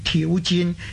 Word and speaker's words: 挑战: 正确嘅咧，个挑字同个战字合起上嚟咧，正确嘅读音咧应该系挑战，挑咧即系引诱咁解。